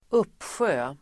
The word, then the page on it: uppsjö: [²'up:sjö:]